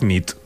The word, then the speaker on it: nit: Y noche es nit. – Muy bien. – Buena nit. Buena nit, es verdad. – Buena nit.